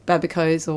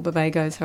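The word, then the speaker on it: babaco: So my garden's too cold for pawpaws, but it's really good for babicos or babagos, however you like to. It's a bit of a tomato-tomato pronunciation thing.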